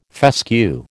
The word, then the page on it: fescue: American English